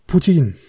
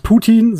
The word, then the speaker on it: Poutine: Mittlerweile ist es Poutine ähm, also nicht Putin, sondern oder auch nicht Pute, sondern ich bin, ich bin des Französischen nicht so mächtig, dass ich das so sauber aussprechen kann. – Ah, alles gut, alles gut. Ich glaube, dass du das richtig aussprichst. Ich meine, das würde man mit OU genau. – schreiben, genau. oder? P-O-U-T-I-N-E.